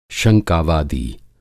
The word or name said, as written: शंकावादी